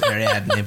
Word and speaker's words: Ariadne: I believe it's Ariadne, but whatever.